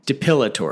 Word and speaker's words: depilatory: Depilatory. – Am I saying it wrong? – You say like debilitatory. – Yeah, that's because like I have a lazy tongue. I know that it's a P, but like it just...